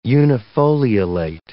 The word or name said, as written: unifoliolate